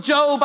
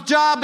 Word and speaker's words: Job: no one told me it was the book of Job. I thought it was Job because it's spelled that way.